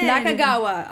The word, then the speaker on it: Nakagawa: Nakagawa.